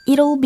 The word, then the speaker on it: it'll: It'll be good in the long run. 아하, it'll 여기에서 all 들으셨죠?